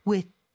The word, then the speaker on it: with: 이럴 때는 th 발음 너무 내려고 하지 마시고요. 그냥 디귿 받침처럼 with 이런 식으로 발음하는 것도 좋을 것 같아요. 그렇게 한번 연습해 볼까요? – I'm coming down with something.